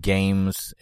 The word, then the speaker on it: GAEMS: It's a company called Games, and it's spelled really oddly. It's like G-A-E-M-S.